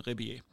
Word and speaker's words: Rebillet: he's he got a French last name, Ribier. That's how I pronounce it.